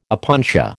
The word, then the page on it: opuntia: American English